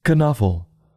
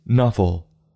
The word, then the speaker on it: Knuffle: Trixie says it's Knuffle, and Sonia says it's Nuffle.